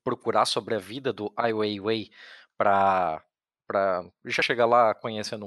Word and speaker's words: Ai Weiwei: É AI, espaço, W-E-I, W-E-I, Ai Weiwei. Isso mesmo. É, do, é praticamente como se fala é,